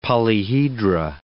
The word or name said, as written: polyhedra